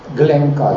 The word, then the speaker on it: Глинка: Я думаю, он даже произносился не Глинка, а Глинка, Гленка даже, как раз на французский манер.